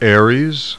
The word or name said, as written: ARES